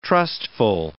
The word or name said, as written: trustful